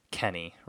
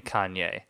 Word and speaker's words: Kanye: really stilted is she tries to pronounce his name Kenny, right? This handler tries to pronounce his name Kenny, and he has this line, "Well, I don't care where you're from, you pronounce my name Kanye," and that's like the really, really rough delivery.